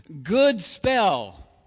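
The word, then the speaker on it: godspel: Good, I believe, it's a long O. Good spell, okay, which means, as you can see, good news, actually, that should be.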